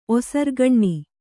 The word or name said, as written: ಒಸರ್ಗಣ್ಣಿ